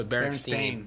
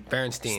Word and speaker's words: Berenstain: No, Berenstain. Berenstain. Stain. I'm no Jewish people, and it's pronounced Steen.